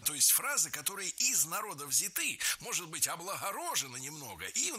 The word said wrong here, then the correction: взяты: Не взяты, а взяты. Ударение в этом слове сохраняется на первом слоге. Он взят, оно взято, они взяты. И только лишь в женском роде ударение переносим на окончание.